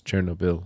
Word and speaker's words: Chernobyl: I know a lady from that area, and she was laughing when I said Chernobyl. She said, it's Chernobyl.